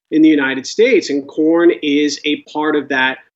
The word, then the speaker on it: Quorn: Quorn, I believe they do pronounce it without the Q-U sounding qua, so it's Quorn, not Quorn, is clearly the star of this company now.